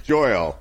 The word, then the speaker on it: Joyal: Joyle. It was close, Joyle.